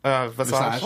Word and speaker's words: Visage: We interviewed her and he said the name wrong to her face. – Vicious. I did. Did I say Vicious? – On purpose? – Yes, you did. You said Michelle Visage.